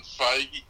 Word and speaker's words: Feige: We're also in New Jersey because apparently, and I didn't know this, Kevin Feige, how do you say his last name? – Feige.